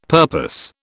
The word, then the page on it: purpose: British English